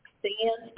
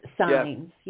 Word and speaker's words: Sainz: How do you pronounce his last name? – Sainz. – Thank you. – Sands, but it's signs.